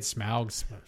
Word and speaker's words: Smaug: Smaug.